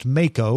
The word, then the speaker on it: Mako: it's pronounced Mako, like the shark.